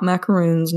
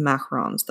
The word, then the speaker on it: macarons: I always feel very self conscious when I say it, but not macaroons, not the coconut ones, the macarons, the f- fancy French ones.